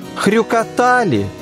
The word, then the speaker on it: Хрюкотали: «Хливкие» следует произносить с ударением на первом слоге, «Хрюкотали» — на третьем, а «Зелюки» — на последнем.